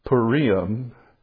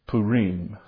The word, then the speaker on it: Purim: They said it's not Purim. And they said if you say Purim, you're really a novice. You really don't even know how to pronounce anything. But the correct pronunciation is Purim.